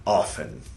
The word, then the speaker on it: often: Often. We'll go with often. No need to pronounce the T that should never have been there to begin with.